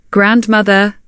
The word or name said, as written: grandmother